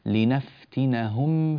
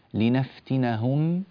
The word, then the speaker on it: لنفتنهم: في الميم الساكنة التي جاء بعدها حرف الفاء، هذه الآية 17 لنفتنهم فيه، لنفتنهم، تنطبق الشفتان انطباقا تاما في الميم الساكنة هنا قبل أن ننتقل بالنطق إلى الفاء، يعني لا بد أن تنطبق الشفتان معا على الميم انطباقا تاما ثم بعد ذلك تنفتحان على الفاء.